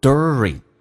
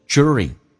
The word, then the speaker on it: during: 一般美式发音发成 during， during，轻轻卷舌 during，那么或者你可以说成 during，地加也发指这个发音叫 during，都是可以。